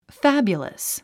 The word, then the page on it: fabulous: fǽbjuləs; ファビュラス